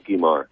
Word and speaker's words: Guimard: Guimard? – Yes. – Okay. – Yes, Cyril Guimard.